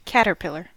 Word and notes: caterpillar: American English

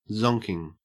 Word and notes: zonking: Australian English

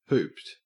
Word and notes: hooped: Australian English

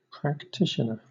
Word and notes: practitioner: British English

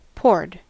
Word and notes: poured: American English

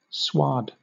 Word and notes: sward: British English

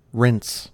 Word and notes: rinse: American English

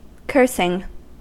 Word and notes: cursing: American English

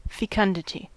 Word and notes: fecundity: American English

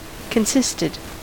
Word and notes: consisted: American English

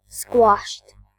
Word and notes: squashed: British English

